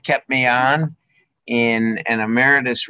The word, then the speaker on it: Emeritus: Did I get that right? I probably pronounced Emeritus. – Emeritus. – Yes.